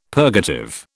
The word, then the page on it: purgative: British English